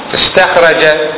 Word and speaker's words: استخرج: استخرج نقول استخرج تنطق فيه، أما إذا خليتها في حال الوصل تقول ما استخرج، ما تنطق لها ألف، ما تنطق الألف، تكتب لكن لا تنطق، بخلاف أكل همزة قطع تقول ما أكل.